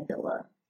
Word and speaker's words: Abilla: And um, Facebook, um, you can find me under my name, Tade Abilla.